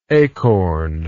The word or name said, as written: acorned